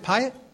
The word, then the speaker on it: Piatt: Piatt is that pronounced it Piatt? Piatt, okay.